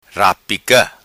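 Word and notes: Rapica: patua

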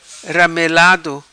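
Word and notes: Ramelado: patua

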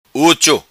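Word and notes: Ucho: Macanese Patuá

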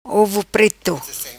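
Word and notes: Ovo Preto: patua

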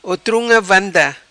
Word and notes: Otrunga Vanda: patua

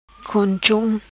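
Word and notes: Gonchom: patua

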